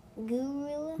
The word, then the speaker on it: guerrilla: Gorilla? – Gorilla. It's pronounced gorilla, but it's not talking about, like, the actual animal.